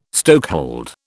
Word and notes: stokehold: British English

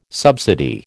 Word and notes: subsidy: American English

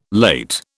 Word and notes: late: British English

